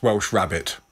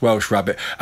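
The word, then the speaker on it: Welsh rarebit: By the way, someone's just texted me to say it is pronounced Welsh rabbit.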